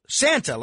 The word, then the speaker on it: Sante: Nah, it's Santa. Santa from Brooklyn. – It's what from Brooklyn? – Santa. Santa. – Spell it for me. – S-A-N-T-E. – S-A-N-T-E. – T-A-T-A. – Santa, like Santa Claus? – Yes, sir.